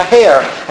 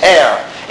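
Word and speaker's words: heir: How do you say that word in English? Air or hair? – Silent H. – Silent H. Okay, silent H. Air.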